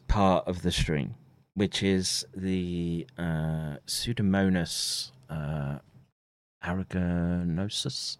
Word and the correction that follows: aeruginosa: Aeruginosa, sorry.